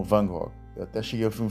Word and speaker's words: Van Gogh: É Van Gogh.